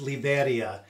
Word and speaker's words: Liberia: It's the word Liberia, but here it's pronounced Liberia.